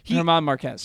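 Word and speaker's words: Germán Márquez: I mean, German Marquez, I don't know how you say it. – German Marquez.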